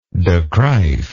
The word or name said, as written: de Kruif